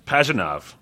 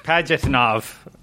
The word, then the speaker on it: Pajitnov: Uh, Tetris creator Alexei, uh, how do you say this? Pajitnov. Thank you.